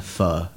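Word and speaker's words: Phở: Die spricht man aus, eine Pho. Also Pho geschrieben, eine Pho.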